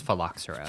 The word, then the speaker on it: phylloxera: I think it's phylloxera.